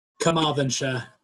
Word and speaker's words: Carmarthenshire: So Nathan Amin grew up in the heart of, now you're going to have to pronounce this for me, Carmarthenshire. – Carmarthenshire. – There you go, West Wales, and has long had an interest in Welsh history, the Wars of the Roses, and the early Tudor period.